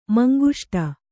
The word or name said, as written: ಮಂಗುಷ್ಟ